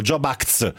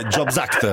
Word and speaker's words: Jobs Act: perché dicevano job acts, mettevano la S in fondo, invece è jobs act.